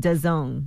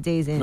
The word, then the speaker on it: DAZN: He said DAZN, the streaming service we're talking about, is pronounced DAZN. – DAZN. – DAZN. – DAZN. – DAZN. – Oh, like DAZN? – Yeah. Just so you know, not Days In.